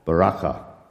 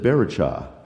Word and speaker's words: Baraka: Someone said Baracha. I said, no, it is not Baracha. That's a good Hebrew person would not do that.